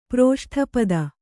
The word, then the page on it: ಪ್ರೋಷ್ಠಪದ: proṣṭhapada